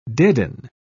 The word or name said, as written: deaden